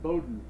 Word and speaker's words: Bowdoin: So how do you pronounce Bowdoin College? You know where that is? – Bowdoin. It's Bowdoin College.